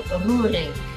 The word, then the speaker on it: Roerink: So yes, in Holland, we roll our R's and the O-E is an O sound. So Roerink, not bad.